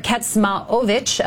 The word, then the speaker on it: Kecmanović: Shapovalov defeated Miomir Kecmanović of Serbia in four sets on Sunday.